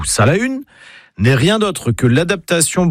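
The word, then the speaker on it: Salaün: Alors, déjà en breton, on prononce Salahune. pas Saint-Lin, mais Salahune, comme le célèbre ermite de la forêt de Landévennec, ou encore du Folgoat, près de l'Esnevin.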